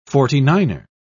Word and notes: forty-niner: British English

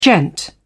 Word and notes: gent: British English

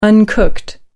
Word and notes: uncooked: American English

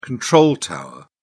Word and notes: control tower: British English

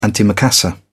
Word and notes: antimacassar: British English

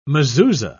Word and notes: mezuza: British English

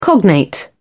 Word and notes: cognate: British English

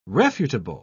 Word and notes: refutable: British English

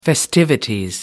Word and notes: festivities: British English

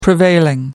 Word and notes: prevailing: American English

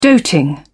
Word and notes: doting: British English

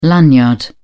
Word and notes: lanyard: British English

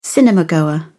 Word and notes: cinemagoer: British English

